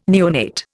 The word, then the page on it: neonate: Indian